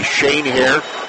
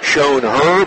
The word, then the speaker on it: Schoenherr: Also keep an eye out for Maddie Shanehair. That's one of those West Central Ohio names spelled S-C-H-O-E-N-H-E-R-R. Looks like Shown Her, but it's pronounced Shane Hair.